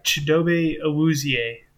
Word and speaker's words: Chidobe Awuzie: And then their cornerbacks are going to be Shido. Oh, wait. Actually, you know how to say this one really good. – Oh, yeah. Chidobe Awuzie.